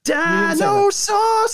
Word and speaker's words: Dinosaucers: You didn't say it right. It's Dinosaucers.